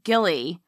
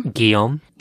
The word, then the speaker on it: Guillaume: Sadly, her son, Gilly, or how do you say this name? – Guillaume.